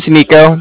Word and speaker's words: Niko: My name's Nico. That's spelled N-I-K-O.